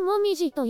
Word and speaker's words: 紅葉: そして、紅葉をもみじと読みますが、紅葉ともみじは同じではないんですね。